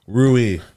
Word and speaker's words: rouille: How do you spell that? That was very good. – Yeah, R-O-U-I-L-L-E. – Rui. – Oh, okay. – It's French.